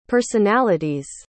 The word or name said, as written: personality's